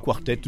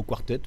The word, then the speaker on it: Quartet: Oui, oui, quartet. Nous, on dit plutôt quartet, mais ça, ça va aussi, Barbershop Quartet.